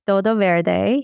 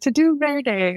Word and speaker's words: Todo Verde: Most people are like, to do Verde.